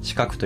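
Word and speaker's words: 鹿角: はい、ブランド名は漢字で鹿の角と書いて四角と読むのですが、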